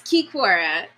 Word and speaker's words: Kikora: It's Kikora.